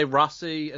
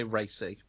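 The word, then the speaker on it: Raci: That, people don't know how to pronounce this because this is his first credit. Some say Rossi and some say Racy. Take your pick.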